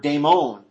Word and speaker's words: daemon: In fact, the word daemon, that we get the word, when you have the word translated demon in your English Bibles, it doesn't tell you anything about it. Because demon is actually a English pronunciation of the great Greek word daemon.